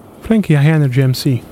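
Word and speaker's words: Frankie hi-nrg mc: Ci ho messo circa 15 anni a imparare a pronunciare Frankie IRMC. – Frankie Energy MC. – Energy MC? Sì. MC eh, Energy.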